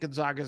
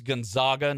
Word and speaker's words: Gonzaga: It's Gonzaga, not Gonzaga.